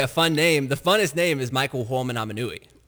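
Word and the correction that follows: Michael Ho'omanawanui: It's Michael Uh-oh Manawanui. – Oh, okay. Uh-oh Aminui. – Oh, he's so funny. – Uh-oh Aminui. – I'm dead serious. Michael Uh-oh Manawanui. – I knew his nickname was Uh-oh. – Yeah. That's how you pronounce it. Uh-oh, Manawanui.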